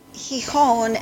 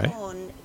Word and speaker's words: Gijón: Well, Gijón and Oviedo are the main towns. – Okay. – Gijón is the seaside town, and it's a port.